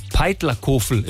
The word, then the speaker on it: Peitlerkofel: Wir sind quasi jeden Monat unten und morgen geht's zum, jetzt richtig aussprechen, Peitlerkofel in einer Hütte.